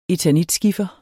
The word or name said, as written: eternitskiffer